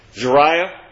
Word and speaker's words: Zeruiah: That was your best pronunciation. – Zeruiah? – Yes.